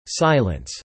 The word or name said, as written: silence